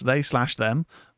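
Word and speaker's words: They/Them: They Them, pronounced they slash them,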